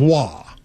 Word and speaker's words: Roy: Yeah, R-O-Y, but it's pronounced Wah.